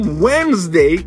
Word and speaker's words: Wednesday: Woden's Day virou Wednesday, que não pronuncia esse D hoje.